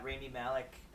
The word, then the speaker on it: Rami Malek: I thought Rami Malek, I think that's how you say his name.